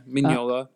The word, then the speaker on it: Mignola: Yeah, Mignola.